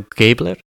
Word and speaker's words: Gebler: This is Daniel Gebler.